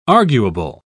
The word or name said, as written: arguable